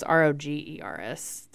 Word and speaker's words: Rogers: No, you spell it different. It's just Rogers. There's no D in there.